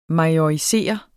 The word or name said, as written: majorisere